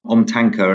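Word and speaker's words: omtanke: But in more recent times, we've seen the introduction of OmniTanky, if I'm pronouncing that correctly. Am I pronouncing that correctly? – OmTanker, and I'm sure that I'm pronouncing it slightly incorrectly, but OmTanker.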